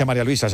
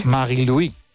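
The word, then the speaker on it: Marie-Louise: No se dice María Luisa, se dice en su... Marie-Louis.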